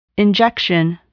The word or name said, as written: injection